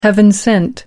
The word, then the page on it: heaven sent: American English